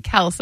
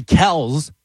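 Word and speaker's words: Kelce: It's actually Kels with a Z is how it's supposed to be pronounced. It has a Z on the end, you know, so Kels, Travis Kels.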